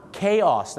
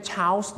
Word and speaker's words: chaos: เขาออกเสียงว่า chaos นั่นเองครับไม่ใช่ chaos นะฮะไม่ใช่ chaos นะฮะเขาออกเสียงว่า chaos ครับ